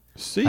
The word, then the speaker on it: Sikh: It's not called the Sikh temple. It's pronounced Sikh Seek. I didn't know that. Yeah, Seek, like okay.